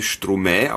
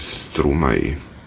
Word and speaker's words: Stromae: Ich habe den sehr lange Stromae ausgesprochen, bis ich mal ihn gesehen habe in einer französischen Fernsehshow. Die behaupten, Stromae.